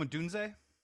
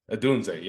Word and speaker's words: Odunze: Oh, Rome Odunze. – Odunze, yep.